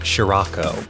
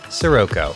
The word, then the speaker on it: Scirocco: Yes, we know that it comes from a real word, which is pronounced Sirako. But in the Japanese, it's Shiroko, and it's a made-up space Jupiter name. And it's spelled S-C-I-R-O-C-C-O. So we're going to keep calling him Sirako.